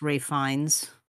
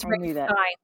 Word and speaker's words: Fiennes: I think it's pronounced Ralph Fiennes. – Thank you. – Yes, it's Ralph Fiennes.